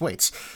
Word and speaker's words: Waits: Yeah, he had it right the first time. It's Waits. – Waits.